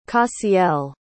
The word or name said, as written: cassiel